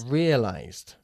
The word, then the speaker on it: realized: No se dice realized. Realized. ¿Por qué? Porque realize como verbo no acaba ni en T ni en D. Ni en un sonido de T ni un sonido de D.